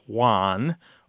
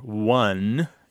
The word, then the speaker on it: won: The pronunciation of W-O-N as Juan or one, you know. I don't know if everyone can hear those differences. So wan, it's like the word wand, W-A-N-D, without mm-hmm. the D. And the other one just right. – sounds like the number, O-N-E, one.